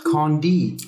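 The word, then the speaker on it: Candie: Weil er es sehr ja. gerne mag, wenn sein Name französisch ausgesprochen wird. Ja, aber er ist ein Arschloch, deswegen spreche ich seinen Namen französisch aus. Candy!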